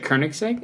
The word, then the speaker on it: Koenigsegg: Is it Koenigsegg? – Yeah.